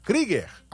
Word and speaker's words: Krieger: Como tem um trema, aqueles pontinhos em cima do U, aí o U tem som de I. Então, não é Germano Kruger, como Fred Kruger, que ele brincou aí o João Bosco, né? É Germano Krieger, a pronúncia do estádio do Operário.